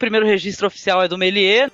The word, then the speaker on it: Méliès: É, Méliès. Méliès fazer o biquinho.